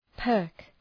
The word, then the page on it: perk: pɜ:rk